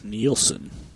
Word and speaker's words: Nielsen: Did we ever figure out, is it Nelson, Nielsen? How do you say that? – It is Nielsen.